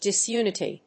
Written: disunity

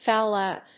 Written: foul out

